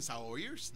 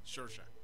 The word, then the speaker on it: Saoirse: Todos los que, la actriz que ustedes les dicen Saoirse, no, no se dice Saoirse, se dice Shorsha.